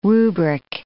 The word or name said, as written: rubric